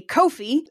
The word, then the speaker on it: Ko-fi: I feel like it's supposed to be pronounced coffee, but also they spell it like Kofi Annan, the old general secretary of the UN, so it's Ko-fi.